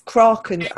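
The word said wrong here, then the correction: Cruachan: Ach, that's not how you pronounce it. I'm sure that's not how you pronounce it. I'm really sorry to everybody who's Scottish laughing at me. It's Kraucan.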